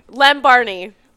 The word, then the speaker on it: Lem Barney: When Barney, right? Is that how you say it? Or Barney? – Lem Barney.